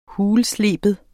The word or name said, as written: HULSLEBET